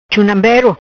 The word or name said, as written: Chunambeiro